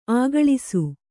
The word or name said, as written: ಅಗಳಿಸು